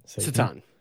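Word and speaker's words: Šatan: Is it actually pronounced Satan? Miroslav Satan? – Satan, yeah.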